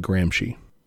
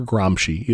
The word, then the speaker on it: Gramsci: I've heard it said Gramsci or Gramsci, either way.